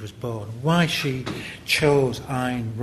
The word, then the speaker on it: Ayn: Uh, and it is Ayn, that's how you pronounce it. If you rhyme it with mine, you'll always remember it.